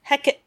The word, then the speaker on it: Hecate: Triple Hecate, which Shakespeare, yes, it's Hecate. Shakespeare's Hecate does not have three syllables. Traditionally, yes, she does, but Shakespeare's Hecate is just Hecate.